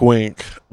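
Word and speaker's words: Wenk: So, did you say his name was Wank? – Wank. – Wink. – So, Wink. – So, oh, Wink.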